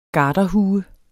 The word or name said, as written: garderhue